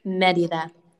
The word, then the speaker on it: Merida: Merida.